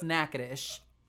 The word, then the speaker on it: Natchitoches: It's spelled like Natchitoches. It's like 27 letters, but it's pronounced Natchitoches.